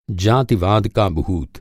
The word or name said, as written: जातिवाद